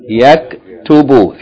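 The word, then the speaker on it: Yaktubu (يكتب): Yaktubu. Three syllables.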